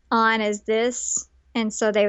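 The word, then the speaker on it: An: Yeah, they pronounce it On. – That makes sense since it's spelled A-N.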